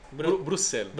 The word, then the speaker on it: Bruxelles: Ho vissuto si dice Bruxelles? – Sì, Bruxelles. – Bruxelles. – No, io sono stato a Bruxelles per fare un tirocinio in un birrificio enorme.